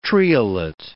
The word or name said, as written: triolet